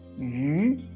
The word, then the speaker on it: 鱼: 授人以鱼不如授人以渔。Comme vous pouvez remarquer, le poisson et la pêche, ça se prononce pareil. Ça s'écrit différemment, mais ça se prononce douce, tous les deux. Yu, yu, yu.